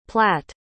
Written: platt

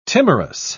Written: timorous

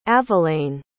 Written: avelaine

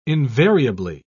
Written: invariably

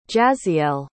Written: jazziell

